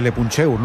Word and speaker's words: Le Puncheur: Le leemos ahí en, no sé cómo se dice, Le Puncheur, no sé cómo se dice eso, Sergio. – No, lo has dicho bien, lo has dicho bien.